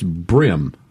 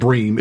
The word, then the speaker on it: bream: It's brim. Is that right? – Correct. – Okay. It's correct. – Not Bream, it's Brim.